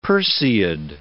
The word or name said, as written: Perseid